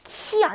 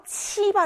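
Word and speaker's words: 치 (七): 이거. 키 아니고 예외, 요거, 요거, 요거 기억하라고 했어요. 치 발음이었습니다. 치. 치, 숫자 7이에요. 숫자 7에서 리을만 빼면 돼요. 치입니다. 치, 일성이고요.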